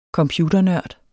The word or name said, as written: computernørd